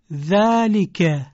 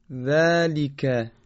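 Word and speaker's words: ذلك: یا حرف ذال باز سر زبانی تلفظ میشه، مثل ذالک. بفرمایید. – ذالک.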